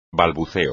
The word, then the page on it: balbuceo: espagnol